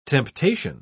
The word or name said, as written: temptation